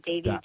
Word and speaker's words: Devi: I keep saying your last name wrong. I'm sorry. – Oh, it's okay. Devi.